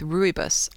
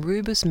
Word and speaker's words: rooibos: And she said that she particularly likes to combine the silver needles with rooibos. I don't know if I'm pronouncing that right. It is R-O-O-I-B-O-S.